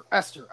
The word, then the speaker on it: Esther: Now that you have – God, is it Ether? Is that how you say your name? – Esther. – Okay, I can never know how – okay, so it's Esther.